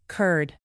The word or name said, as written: curd